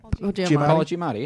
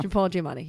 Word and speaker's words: Giamatti: Paul Giamatti. – Yeah. – Paul Giamatti.